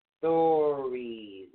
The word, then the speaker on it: Thurisaz: This one is actually spelled T H U R I S A Z. But it is pronounced thurizaz. Thurizaz.